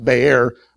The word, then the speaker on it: Beer: In Numbers chapter 21, the children of Israel are out in the wilderness and come to a place called, it's spelled Beer, B-E-R. It's actually pronounced Bear, but it's not talking about Bud Light or Dos Equis, so don't let your mind go that direction.